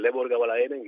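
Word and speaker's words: Lebor Gabála Érenn: Aquí en el Lebor Gabala Erem, y lo has pronunciado muy bien, no tengas tanto miedo.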